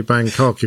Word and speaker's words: Bangkok: So, could you pronounce that differently, please? – I don't think you can. I think you have to say Bangkok if you're going to Bangkok.